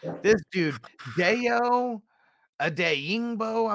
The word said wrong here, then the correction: Odeyingbo: I'm not even sure how the hell to pronounce his name. – Odeyingbo. – Yeah, there you go.